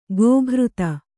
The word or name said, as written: ಕುಕೃತ